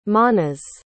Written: manas

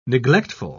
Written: neglectful

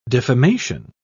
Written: defamation

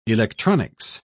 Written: electronics

